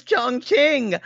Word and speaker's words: Chongqing: Y'all, I promise you the name is Chongqing. I promise you it is spelled this way. C-H-O, this is in pinyin, by the way. C-H-O-N-G-Q-I-N-G. Chongqing.